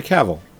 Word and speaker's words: Cavill: Am I pronouncing that right? – Henry Cavill, yeah. – Cavill. – You got it.